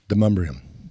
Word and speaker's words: Demonbreun: D-E-M-O-N-B-R-E-U-N. It's spelled D-E-M-O-N. O-N-B-R-E-U-N. – Demumbrium.